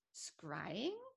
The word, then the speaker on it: scrying: about scrying.